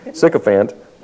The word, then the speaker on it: sycophant: Was I pronouncing it right, by the way? Is it sycophant? Sick and tired of fant. Okay, sycophant.